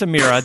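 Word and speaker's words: Emira: I'm not going to screw up this one. It is the Amira. I reached out to them and I was like, tell me exactly how to say this.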